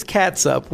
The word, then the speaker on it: catsup: You may use this condiment every day, but you probably pronounce it differently. – This is catsup with a C, C-A-T-S-U-P. Now, you'll be hard-pressed to find anybody around here that actually pronounces it catsup.